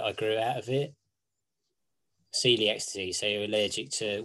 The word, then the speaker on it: celiac: And so they've been brewing venture began when Jonathan, so that would be the son, I guess, was diagnosed with celiac disease. I'm sorry if I'm pronouncing this wrong. I've not... – Celiac disease, yeah.